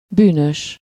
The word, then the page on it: evil: American English; IPA : /ˈiːvəl/; IPA : /ˈiːvɪl/; IPA : /ˈiːvil/